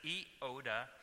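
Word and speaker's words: Euodia: I looked the pronunciation up, and it wasn't an easy one, and I wouldn't have predicted it would have been said like that, but it's Euoda and Syntyche.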